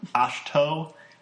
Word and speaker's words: Ahch-To: Opens up with, fade from black onto the island of, is it pronounced Ahch-To? Ahch-To? – It's A-H-C-H-T-O. – Ahch-To? – Ahch-To?